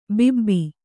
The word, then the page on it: ಬಾಬು: bābu